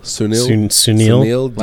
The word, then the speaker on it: Sunil: What's Sunil. – The D part? – I mean. – Sunil? Okay. – The letter D. – Sunil. – Sunil D.